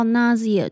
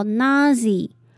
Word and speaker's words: nausea: 再来我们看到 nausea 这个字，开头的 A-U 我们要发 R 的音，那后面的 S 呢则是发 Z 的音， S 后面的 E 则是发 E 的音，念作 nausea， nausea。那最后的 A 呢则是会发 Y 的音， Nausea， Nausea。